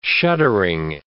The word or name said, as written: shuddering